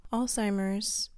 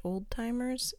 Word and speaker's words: Alzheimer's: Alzheimer's, by the way, is not pronounced old-timers.